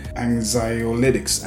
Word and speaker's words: anxiolytics: And then the other class is your minor tranquilizers, which are medically known as anti-anxiety agents or anxiolytics. Anxiolytics, that's how you say it.